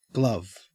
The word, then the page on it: glove: Australian English